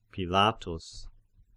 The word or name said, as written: Pilatus